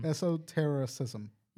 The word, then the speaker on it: Esotericism: So astral projection is or also known as astral traveling, is a term in egoism to describe in how do you say that word? – Esoterism. – Dream terrorism. – Esotericism. – Yeah, that right.